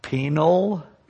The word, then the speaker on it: penal: And the complete term that you'll often hear, and you have to say it carefully, but it's the penal, P-E-N-A-L. I hear pastors mispronounce it all the time as an embarrassing word. Penal, that is, it's penalty, substitutionary atonement.